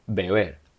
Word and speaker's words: beber: So the first B gives the sound b, b, b. The second one gives w, w, w. So to drink is pronounced as beber, beber, beber.